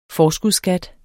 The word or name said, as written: forskudsskat